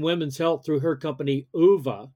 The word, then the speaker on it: OOVA: We do pronounce it OOVA, but there is the intent of having the word OOVA in there, obviously.